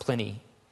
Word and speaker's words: Pliny: I always want to say Pliny, but it's pronounced Pliny.